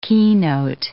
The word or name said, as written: keynote